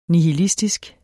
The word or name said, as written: nihilistisk